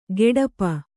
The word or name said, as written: ಗೆಡಪ